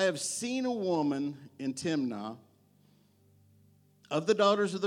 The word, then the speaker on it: Timnah: It says, now Samson went down to Timnah. That's the Hebrew way to pronounce it. I would have said Timnah.